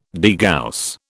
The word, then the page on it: degauss: British English